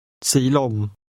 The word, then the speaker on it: Silom: ขอโทษครับถนนสีลมอยู่ที่ไหนครับ Silom is pronounced with a combination of rising and mid-level tones. Listen and repeat. – Silom.